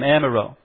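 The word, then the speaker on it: Amyraut: That's how you say his name, Amiraud. Maurice. Now, most of us English people, we'd probably say Moise Amiraud. But it's supposed to be Maurice Amiraud.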